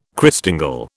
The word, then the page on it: Christingle: British English